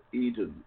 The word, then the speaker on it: Eden: If I can't find you, you can find me on Facebook as Bob Eden, E-D-E-N, like in the Garden of Eden.